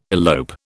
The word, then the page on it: elope: British English